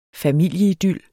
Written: familieidyl

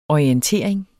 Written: orientering

